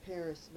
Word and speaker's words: Perris: And then we took a place of our own in Paris Valley. which is spelled PERRIS.